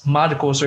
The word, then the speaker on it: Marcos: Like I had the same major advisor for 4 years, and this woman called me Marcus, both to my face and in text, when she had access to my records, and my name is Marcos, or if you can't pronounce that, like Marcos, right? – Right. – But she went out of her way to replace that O with a U every chance she got.